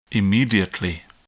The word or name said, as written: immediately